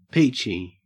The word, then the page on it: peachy: Australian English